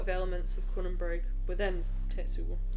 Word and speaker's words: Tetsuo: Am I pronouncing that right? – Tetsuo. We've seen Akira.